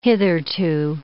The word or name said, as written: hitherto